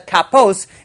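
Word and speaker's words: kapos (כפות): Although we pronounce it as kapos, it's written as kapas, which is the singular.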